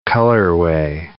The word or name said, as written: colorway